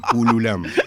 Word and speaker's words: Kulwam: Se escribe K-U-L-W-A-M, Cululam.